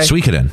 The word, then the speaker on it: Suikoden: Suikoden is one that I mean is that how you say it? – Yes. – Yeah. – Okay. Suikoden. – I always thought it was Suikoden. – Yeah. – Because that's how Psycho Mantis says it.